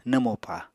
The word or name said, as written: nwmopa